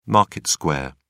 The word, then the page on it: market square: British English